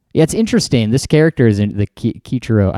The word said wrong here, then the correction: Kichijiro: I don't know if I'm saying it right. – Right. – Kichijiro? – Yeah. – I think it's Kichijiro. It's interesting.